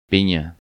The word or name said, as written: Pina